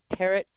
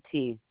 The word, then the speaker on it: Parrott: My name is Christine, Kristine, Parrott, Parrott. – Okay, I'm sorry. Spell that last name once more for me. – P, like the bird, Parrott, Parrott. Two T's on it, though.